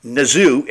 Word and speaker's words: Nezu: Um, you know, I've been saying his name wrong. It's Nazoo. It rhymes with kazoo.